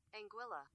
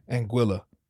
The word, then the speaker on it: Anguilla: Anguilla. – You heard the woman. Anguilla.